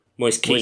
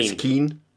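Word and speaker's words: Moise Kean: Moise Keane. – Moise Keane. – I believe it's pronounced like Roy Keane.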